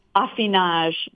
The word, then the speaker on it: affinage: A-F-F-I-N-E-U-R? – Right, yep, affineur practices affinage, which rhymes with massage,